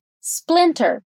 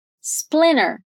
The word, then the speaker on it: splinter: Repeat: splinter. É comum também pronunciar essa palavra quase sem esse T, né? Com um T muito, muito suave. Vai ficar splinter. As duas pronúncias são comuns no inglês americano, então você pode escolher qual você se sente mais confortável.